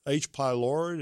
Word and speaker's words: H. pylori: Well, maybe this H. pylori, is that how you pronounce yes.